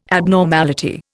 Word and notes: abnormality: Indian

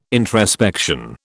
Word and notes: introspection: British English

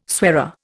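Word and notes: soirée: Indian

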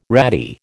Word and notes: ratty: American English

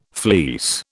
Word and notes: fleece: British English

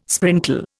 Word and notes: sprinkle: Indian

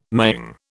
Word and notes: maying: British English

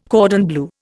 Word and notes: cordon bleu: Indian

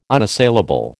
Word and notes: unassailable: American English

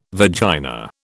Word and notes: vagina: British English

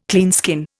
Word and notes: cleanskin: Indian

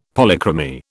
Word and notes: Polychromy: British English

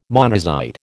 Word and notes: monazite: American English

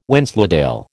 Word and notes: Wensleydale: American English

